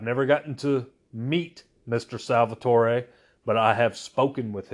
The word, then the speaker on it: Salvatore: Salvatore, or Salvatore. I believe the correct pronunciation is Salvatore, but he is the writer, the creator of the dark elf character Dritzt, and I own every book that Dritzt is in.